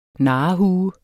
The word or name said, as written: narrehue